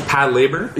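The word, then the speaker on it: Patlabor: It's Pat Labor. – Yeah, Pat Labor. Okay. Yeah, it's like patrol labor, but like shortened into.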